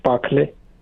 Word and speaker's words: Pakhale: Mahesh Pakhale. – How do you say? P-A? – P-A-K-H-A-L-E. – K-H-E-L-E. – K-H-A-L-E.